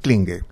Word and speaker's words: Klinge: Vos sabés que el apellido Klinge, porque así se pronuncia. – ¿Cómo se pronuncia? – Klinge. – Klinge.